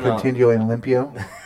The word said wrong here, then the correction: mantenlo limpio: I said that wrong. Dad, how do I say it? You corrected me last time. Dude, how do I say it? – Mantenlo limpio. – Yeah, mantenlo limpio.